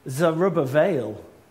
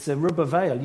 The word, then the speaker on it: Zerubbabel: And you can pronounce it how you like, but I doubt whether many people, unless you really are a Hebrew scholar, will ever pronounce his name correctly, because it's actually Zerubbabel. So for all those people who've seen the word looks like Zerubbabel, it's Zerubbabel.